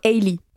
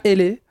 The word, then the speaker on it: Hailey: Hailey. – Ok, ça va. Tant que vous ne dites pas Elle.